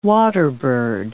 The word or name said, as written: waterbird